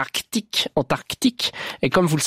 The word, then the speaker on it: Arctique: j'ai toujours prononcé Arctique et Antarctique sans le C.